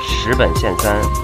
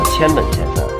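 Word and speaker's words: 辻本憲三: 非常抱歉的和大家说一下，在本期节目中我们犯了一个错误，将社长的名字十本线三念成了千本线三，这也是由于本人长期以来的习惯性念法所导致。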